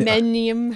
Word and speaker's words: hymenium: And one of the ones that I really appreciate, and I thought the name was very clever as well, is the, the hymeninium. I can't even pronounce it. – Oh yes. Hymen. Hymen, hymenium, hymenium, hymenium, hy- hymen, and then uh, neum, neum. So it's like um, a flower, and obviously hymen.